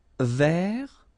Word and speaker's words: vert: Vert. So masculine form, don't pronounce the final T.